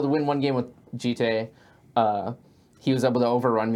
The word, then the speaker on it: Jitte: Nothing tilts me more than people calling it like Jit or Jeet, I heard once. It's like Kamigawa is a Japanese set. It's pronounced Jitte.